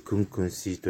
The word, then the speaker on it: 工工四: え今日はですね、えー、今回は、あのー、沖縄の音楽に、えー、使われている譜面のフォント、クンクンシー、工事の工という字を 2 つ書いて、 4 という文字ですね。で、それを書いて、で、クンクンシーと読むわけですが、